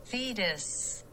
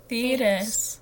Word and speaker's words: Thetis: Oh, that sounds like lettuce. I don't think I'm saying it properly. – Here, I'll look it up for you. – I looked up how to pronounce things, too, and I forgot. – Okay, let's listen to Google's pronunciation. Oh, wait, that was not it. – Thetis. – Thetis. – Okay. So I pronounced it right the first time, or the second time I said it, or whatever time I said it.